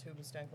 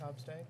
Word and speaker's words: Hoobastank: All right, so for my next trick, I will pull Hobstank out of a toxic family environment. – You did not just pronounce Hoobastank like Hobstank? – I can't remember who the fuck Hoobastank is.